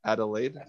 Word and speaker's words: Adelaide: I think I'm saying the name right. You could probably help me, Drew. Adelaide, is that right? – Sounds right to me.